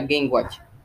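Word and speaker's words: Game & Watch: se escribe Game I Watch, pero se dice Game, la Game Watch.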